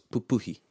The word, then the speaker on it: pupuhi: Just with that word, titiro, whenever we have this double up in the two first syllables, papaki, titiro, pupuhi. So you got the two first syllables in a word of three syllables. The stress is on the middle one.